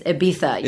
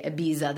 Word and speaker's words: Ibiza: it's Ibiza. Saying it wrong. I'm like, I know, but we say Ibiza in the U.S. – Yes, I'm sorry. – I'm so sorry. I know that it should be Ibiza.